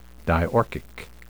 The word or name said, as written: diorchic